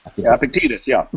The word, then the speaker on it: Epictetus: Epictetus, Epictetus.